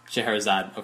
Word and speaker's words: Scheherazade: Scheherazade.